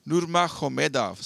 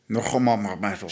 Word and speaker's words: Nurmagomedov: Then he's got his other middle name, and then it's Nurmagomedov. So his G is pronounced like an H. – Nurmagomedov.